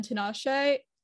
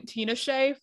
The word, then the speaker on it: Tinashe: Tinashe. That's the correct pronunciation. I was saying Tinashe for like years and years, and I only like just learned the correct pronunciation.